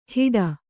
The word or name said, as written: ಹಿಡ